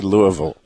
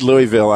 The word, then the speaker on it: Louisville: I don't know how to say Louisville. I said Louisville. I know. But now that I heard you say it, I realize there's a better way to say that.